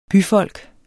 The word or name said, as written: byfolk